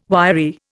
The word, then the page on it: wiry: Indian